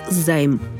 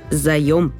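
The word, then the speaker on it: заём: Слово «заем» часто и произносят, и пишут как «займ». Такая ошибка возникает из-за склонения слова «договор займа» – «дать взаймы». «Займы» – все это правильно, но это не значит, что в именительном падеже единственного числа это слово пишется как «займ». Правильно – «заем».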